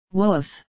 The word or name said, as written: woof